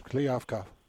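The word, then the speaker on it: Kliavkoff: What type of relationship do you have with the commissioner, and what have been some of your first thoughts of George Kliavkov? – First of all, nice job on the pronunciation.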